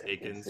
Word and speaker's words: Eakins: I'm always going to mess that up. It's Eakins. Yeah. Eakins. I swear I'll get like, it. Yeah. The E is silent. Don't say it.